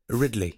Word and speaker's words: Ridley: Est-ce qu'on le prononce Ridley? – Ridley. – Ridley. – Ouais.